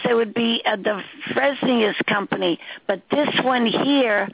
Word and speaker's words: Fresenius: Fresenius. – Fresenius? – Mm-hmm. Fresenius. – Oh, that's how you pronounce it, Fresenius.